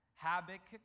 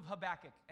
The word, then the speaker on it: Habakkuk: Habakkuk. The book of Habakkuk.